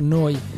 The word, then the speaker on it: Neu: Si scrive Neu, si pronuncia Noi, e vuol dire nuova emittente urbana.